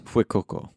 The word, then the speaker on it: Fuecoco: Fuecoco? – Fuecoco.